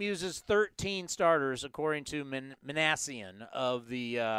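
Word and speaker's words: Minasian: How do you say his name? The Angels GM? – Perry Manassian.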